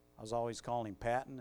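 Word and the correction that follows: Paton: and then somebody said, no, it's really Peyton.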